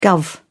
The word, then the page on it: guv: British English